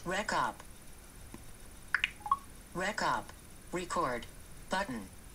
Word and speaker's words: RecUp: I'm assuming you pronounce it RecUp. It's all one word, R-E-C-U-P.